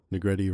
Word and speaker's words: Negrete: Negrete rhymes with spaghetti, and went on to do Walking Dead World Beyond.